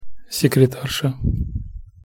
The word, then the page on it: sekretarsa: Russian